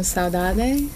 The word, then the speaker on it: saudade: Actually, I love this term, saudade.